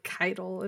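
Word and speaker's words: Caiatl: Like, Maddie, Mylan Games, and everybody firstly decided it was Kyattle because there was, like, no determination on how it was pronounced. And then the trailer came out, and they're like, Kytle. And I'm like, where did the other A go, guys? And so my feedback is, Bungie, do you know how to pronounce the own word in your trailer? Because I don't think you do.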